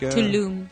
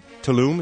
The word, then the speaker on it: Tulum: Tulum. – Tulum, is that how it's pronounced? – Yes, it's near Cancun.